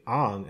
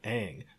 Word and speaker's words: Aang: So you say Ong instead of Aang because it's correct.